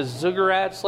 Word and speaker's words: ziggurats: Usually the what would be known as the ziggurats later, ziggurats, how do you say that? – Ziggurats.